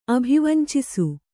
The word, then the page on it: ಅಭಿವಾಂಛಿಸು: abhivāñchisu